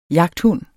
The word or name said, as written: jagthund